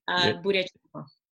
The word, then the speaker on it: Bureacenco: Bureacenco.